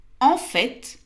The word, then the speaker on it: en fait: En fait, et on prononce le T, veut dire effectivement, en réalité, au fond.